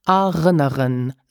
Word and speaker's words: erinnern: Did you notice that the verb is pronounced erinnern, sich erinnern with a final N? And to say I remember, Ich erinnere mich. So you hear that we drop the final N of erinnern.